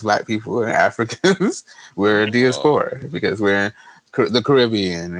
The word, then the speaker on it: Diaspora: Diaspora.